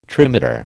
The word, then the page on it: trimeter: American English